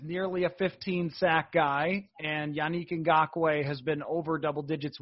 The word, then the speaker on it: Yannick Ngakoue: It's Yannick Ngakoue, right? – Yannick, yes.